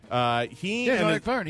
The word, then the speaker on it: Vergne: The Formula E sanctioning body fined the winner of the race, and I don't want to mispronounce his name. I think it's Jean-Éric Vergne. – Vergne. He used to race in F1. – Good. Then I was a lot closer than I thought.